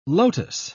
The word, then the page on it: lotos: British English